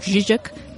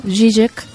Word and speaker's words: Žižek: Eh, un científico como, o sea, un filósofo como Slavoj Žižek, porque pues se pronuncia así. – A ver, a ver, a ver, a ver de nuevo. Žižek.